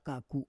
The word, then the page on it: kaku: Roon